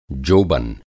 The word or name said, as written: जोबन